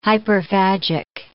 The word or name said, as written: hyperphagic